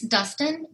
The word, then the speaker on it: Dustin: It's Dustin, D-U-S-T-I-N.